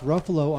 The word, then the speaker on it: Ruffalo: Just by the way, in watching Mark Ruffalo on Letterman, yep. – I learned something very important. – Yes. – It is Ruffalo, rhymes with buffalo.